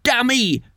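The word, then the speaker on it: Damé: And there we go with Dammy. I believe it's pronounced Dammy. It's D-A-M-E. It's got an accent over the E. Dammy, Dammy. Like a rock star.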